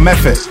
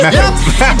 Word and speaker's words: Medford: Except it's called Medford. That's how you say it. Medford.